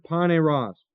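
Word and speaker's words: poneros (πονηρός): And this word evil is the Greek word poneros, P-O-N, long E-R-O-S.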